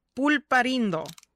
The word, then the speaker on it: Pulparindo: The the candy itself is called pulparindo. – Pulparino. Pulparindo. – Pul- pulparindo. – I'm not even going to You got to roll your R there.